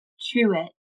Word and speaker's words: Truitt: Now, I am listed under Truitt, which is spelled T-R-U-I-T-T, Truitt, Intuitive Connections.